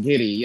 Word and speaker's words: Giddey: Yeah, I think it's pronounced Giddey.